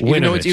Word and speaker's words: Winovich: Are we saying it wrong? It's Vinovich? – Winovich. – Even though it's got a W? – Oh, I'm sorry.